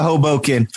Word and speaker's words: Hoboken: Okay, we are headed over to Hoboken. Did I say it right? – Yeah, we're going to Hoboken, okay.